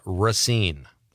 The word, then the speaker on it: Racine: And from the 608, Racine does not have a long A. It's pronounced Racine.